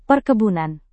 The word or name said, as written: perkebunan